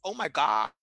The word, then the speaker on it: Omaga: Och i år då så ska Omaga helt enkelt uttalas Oh my God.